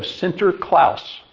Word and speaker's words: Sinterklaas: And they brought with them the tradition of Sinterklaas.